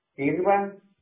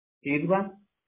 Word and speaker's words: Irvine: La otra sería, Irvan. – ¿Cómo, perdón? – Irvan. Es como el mine, así. – ¿Es como qué, perdón? – Se escribe como Irvine, algo así. – Ah, ok, Irvine.